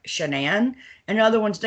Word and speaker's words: Shannan: But for those of you who don't, so I might butcher her name because it is not Shannon like I thought it was. It's Shannan. – No, it's not. – It's Shannan.